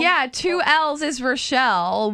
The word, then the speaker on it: Rachelle: It's Rochelle. You do that every time, but it's okay. – Well, sorry. – Way to go, Mark. – I mean, it's spelled Rachel. Talk to Justin. I can't. – Really? Because I spelled it for him, too. – How do you spell it? – R-A-C-H-E-L-L-E. – Oh, well, see, that looks like Rachel to me. – No, that looks like Rochelle.